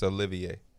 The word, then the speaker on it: Olivier: Do you know the name Oliver Sarr? – You're saying his name wrong. It's Olivier. – Oh, see, I saw Oliver. – Yeah, it's spelled like Oliver.